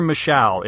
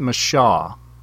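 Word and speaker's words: Micheaux: Actually, it's Oscar Michaud. It is spelled, kind of looks like it would be Michaud, but he actually pronounced it Michaud.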